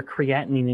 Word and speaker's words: creatinine: Increasing fats and reducing carbs has lowered my serum creatinine. Is that how you say it? – Creatinine.